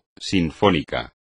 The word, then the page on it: sinfónico: espagnol